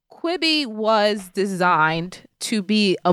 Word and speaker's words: Quibi: They write, "Was Kibby or Quibi? – How Quibi.